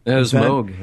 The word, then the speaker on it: Moog: Is that Moog or Moog? – Moog. It's pronounced Moog. Is it pronounced Moog, really? – It is pronounced Moog.